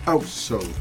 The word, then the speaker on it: Hacksaw: Possiamo correggere la pronuncia Covers di Hacksaw, perché sarebbe Hacksaw.